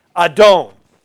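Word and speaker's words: Adon (אָדוֹן): This is a silent letter, this Aleph. with a Kamatsko-Don in there, that's A sound. D-O-N. Adon.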